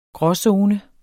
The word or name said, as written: gråzone